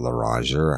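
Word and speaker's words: Loranger: Y'all don't know about LaRanger or how it's spelled. It's LORRANGER. And it's LaRanger is a very rural place in Tangipahoa Parish.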